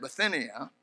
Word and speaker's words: Bithynia: And after that, they were to Mysia and essayed to go, and I pronounce that Bithynia, and the Spirit suffered them not.